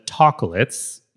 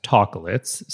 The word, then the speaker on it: Tacolets: I recently got my hand on the Tacolets. Yes, Tacolets, spelled like chocolate, but Tacolet.